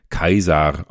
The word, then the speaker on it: Caesar: Der Name Caesar wurde in der Antike Kaisar ausgesprochen, also Caesar ist Kaiser.